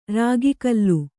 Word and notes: ರಾಗಿಕಲ್ಲು: rāgi kallu

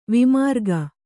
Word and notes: ವಿಮಾರ್ಗ: vimArga

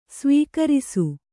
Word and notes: ಸವಕರಿಸು: savakarisu

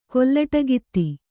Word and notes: ಕೊಲ್ಲಟಿಗಿತ್ತಿ: kollaṭigitti

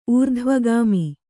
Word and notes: ಊರ್ಧ್ವಗಾಮಿ: ūrdhvagāmi

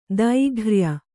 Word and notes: ದೈಘ್ರ್ಯ: Kannada; dairghya

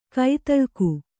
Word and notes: ಘಾತಾಳಿಕೆ: kannada

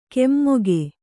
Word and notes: ಕೆಮ್ಮೊಗೆ: Kannada

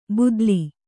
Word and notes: ಭೂತಾಲಿ: Kannada

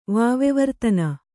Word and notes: ವಾವಿವರ್ತನೆ: Kannada

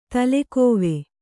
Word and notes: ತಲೆಗವಿ: kannada

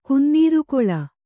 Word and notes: ಕಿನ್ನರಿಗಲ್: kinnarigal

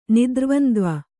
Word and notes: ನಿದ್ರ್ವಂದ್ವ: Kannada

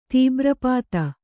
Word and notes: ತೀಬ್ರಪಾತ: kannada

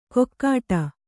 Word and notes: ಕೊಕ್ಕಾಟ: kokkāṭa

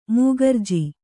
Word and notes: ಮೂಕರ್ಜಿ: mūkarji

